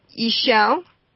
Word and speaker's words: Ixchel: And the other one is Ishel, and it's spelled I-X-C-H-E-L.